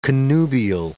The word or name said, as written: connubial